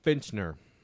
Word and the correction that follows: Fichtner: Is that how you say his name? – I think it's Fichtner. – I don't know. It's spelled F-I-C-H-T-N-E-R.